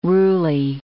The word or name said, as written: ruly